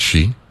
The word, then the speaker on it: Xi: So I see the president is currently in China, where he's meeting with President Xi. And that is spelled X-I, or pronounced S-H-E.